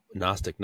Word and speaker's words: Gnostic: Gnostic, what is that? Well, the G is silent, G-N-O. And it's the same word that we have in English for knowledge, no. The K is silent.